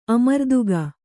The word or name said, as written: ಆಮ್ರಾತಕ